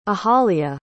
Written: ahalya

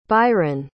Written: biron

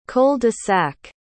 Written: cul-de-sac

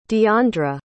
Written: diondra

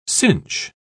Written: cinch

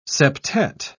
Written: septet

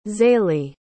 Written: zaylee

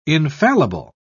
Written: infallible

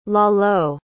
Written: Lalo

